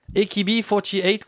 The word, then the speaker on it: AKB48: parce qu'il faut le prononcer à l'anglaise. akb 48 qu'on vient d'écouter donc sur Radio Campus Paris.